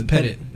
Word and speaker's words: Pettit: Last night we learned the Bob Petit, Pettit, Bob Petit or Pettit. I don't actually don't know the pronunciation for his last name. – Is it an E at the end or no? – It's not. So it's Pettit. Okay, yeah. Pettit.